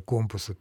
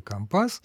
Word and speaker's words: компас: Так же знаменитый пример компаса «Компас». Значит, на флоте принято вроде бы говорить «компас», а не «компас», в отличие от всего остального литературного языка.